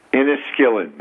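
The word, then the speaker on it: Inniskillin: It's Inniskillen. That's how it's pronounced.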